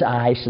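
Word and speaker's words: ice (εἰς): He's sitting right behind me, and I said, Brother Edwards, how do you pronounce that word? He said, it's ice.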